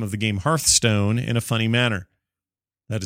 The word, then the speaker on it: Hearthstone: We pronounce it Hearthstone, same as you.